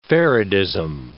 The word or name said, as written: faradism